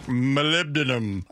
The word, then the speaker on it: molybdenum: molybdenum and lithium and others and things like that. – It's science. – Molybdenum. And I said it right, by the way.